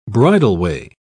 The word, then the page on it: bridleway: British English